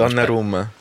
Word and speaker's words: Donnarumma: What is interesting is the Italian squad and Donnarumma. Did I pronounce it? Go on, say it for me, because you say it. – Donnarumma. – Yeah, you see.